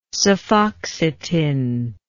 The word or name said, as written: cefoxitin